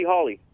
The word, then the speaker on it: Hawley: Yeah, Sandy Hawley. – How do you spell it? – H-A-W-L-E-Y.